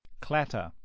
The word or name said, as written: clatter